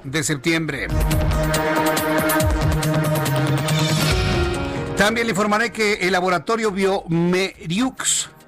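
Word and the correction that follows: bioMérieux: Latinoamérica, bueno, la, la pronunciación sería Biomerio el laboratorio Biomerio Latinoamérica presentó en México la prueba PCR múltiple para detectar coronavirus o COVID-19 en tan solo 45 minutos.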